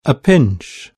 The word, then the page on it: pinch: British English